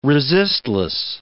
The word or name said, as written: resistless